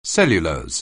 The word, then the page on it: cellulose: anglais